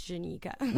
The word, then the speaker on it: Jenica: I wasn't going to correct you on radio, but guys, it's Jenica. – With a J. J-E-N-I-C-A.